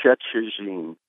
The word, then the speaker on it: Szczecin: It's a Polish, it's a Polish lake, and I believe the correct pronunciation is Szczecin.